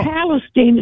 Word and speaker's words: Palestine: Palestine. It's spelled like Palestine, but Palestine.